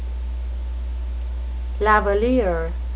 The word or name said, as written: lavaliere